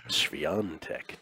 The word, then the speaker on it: Świątek: I had already made a bet on my girl, your girl, Iga Swiatek, to win. Świątek. To win. Yeah. Proper Polish pronunciation, pardon me. To win this. – No, you nailed it.